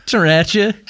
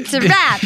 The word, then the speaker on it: sriracha: Yeah, well, you're not quite saying it right, but it's okay. It's a foreign word. Sriracha. And so, you know, it's a great. – There's an almost silent D at the beginning. – Yes. It should sound hard. – Sriracha. – Yes. Yes. It should sound like it hurts to say.